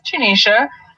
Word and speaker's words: Janeisha: So my Instagram, if you guys want to connect, is called Journey with Janisha. My name is spelled J-A-N-E-I-S-H-A.